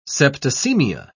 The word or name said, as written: septicemia